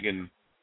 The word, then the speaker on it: Seguin: Chris Kreider, who got hit in the forehead by Tyler Seguin's stick blade. – Sagan.